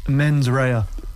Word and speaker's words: mens rea: Would you pronounce the Latin for me properly, please? – Mens rea, actus rea. – See, that's better.